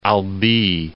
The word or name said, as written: Albi